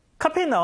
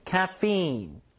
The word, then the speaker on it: caffeine: 카페인 나와야죠. 카페인 영어 발음이 참 이게 어렵습니다. 자, 들어보세요. 카페인. 카페인. 일단 카는 아니고 약간 캬에 가깝고요. 뒤에다 강세를 주시고 이제 좀 끌어주셔야 돼요. 카페인.